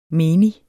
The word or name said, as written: menig